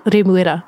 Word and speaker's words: Remuera: Remuera.